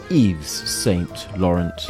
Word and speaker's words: Yves Saint Laurent: Laurent, not to be pronounced YSL, Saint Laurent, or Yves Saint Laurent. It is Yves Saint Laurent.